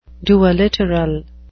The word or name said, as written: duoliteral